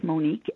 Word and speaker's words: Monique: And my name is spelled Monique, M-O-N-I-Q-U-E,